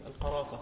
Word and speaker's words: القرافة: وكان شيخه يسأل أين القرافي، وهنا في مصر تنطق بالتخفيف القرافة.